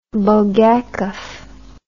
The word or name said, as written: Bulgakov